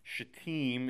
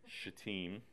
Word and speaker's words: Shittim: A-C-A-C-I-A. – Sweet. Okay. Shittim is the way that is pronounced, by the way. Don't pronounce it like my buddy Nick Wrighton did in our residency program. Okay. Shittim.